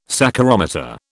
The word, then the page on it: saccharometer: British English